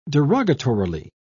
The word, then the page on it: derogatorily: British English